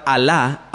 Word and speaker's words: Alá: Ahora, cuando usted escucha la palabra Alá, A-L-A, con acento en la segunda A, eso es su Dios, ¿bien?